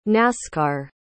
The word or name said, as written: nascar